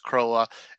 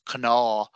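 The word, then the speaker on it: cnámha: It's pronounced Croa. In Munster Irish, it's pronounced Canaw. And in Connacht Irish, it's pronounced craw, craw.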